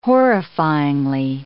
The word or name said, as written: horrifyingly